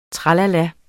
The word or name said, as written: tralala